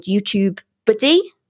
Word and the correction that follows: TubeBuddy: Did I say it right? TubeBuddy. TubeBuddy.